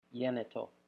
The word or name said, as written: yenito